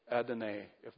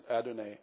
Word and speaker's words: Adonai: That is the word Adonai. I think that's the way you pronounce it, Adonai.